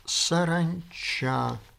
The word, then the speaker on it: Саранча: Василий Семенович Саранча, я проверил, ударение стоит именно на последнем «а», инженер, город Орел.